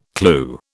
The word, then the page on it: clue: British English